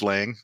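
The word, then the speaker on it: Lange: It looks like Range, but it's Lang.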